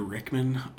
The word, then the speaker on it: Rickman: Sure, Peter Rickman, R-I-C-K-M-A-N.